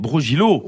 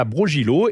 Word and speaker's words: Brogilo: Brogilo. – Voilà, Brogilo, écrit B-R-O-G-I-L-O,